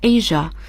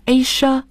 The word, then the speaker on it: Asia: 那么我们亚洲大家都了解叫 Asia， A-S-I-A。很多听众朋友会说，咦，我听到的发音是 Asia，那么两种发音 Asia Asia，现在 Asia 这个发音在美国更时髦一些。 – Asia，它的形容词形式就是 Asian。 – 那么我们说亚洲的亚洲人就是 Asian。